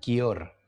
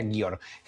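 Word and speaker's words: Győr: Yo lo pronuncio como Gyor, pero seguramente aquí me van a ver feo diciendo así no se pronuncia, pero yo diría Gyor. G-Y-O-R.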